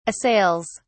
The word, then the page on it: assails: British English